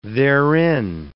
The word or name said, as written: therein